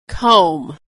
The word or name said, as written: comb